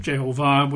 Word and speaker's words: Jehovah: I'm fairly convinced Yahweh is wrong, and I'm reasonably convinced Jehovah is right, or Jehovah, I think they would pronounce it, Jehovah, with the emphasis on the last syllable, Jehovah.